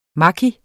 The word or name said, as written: maki